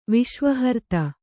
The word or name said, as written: ವಿಶ್ವಹರ್ತ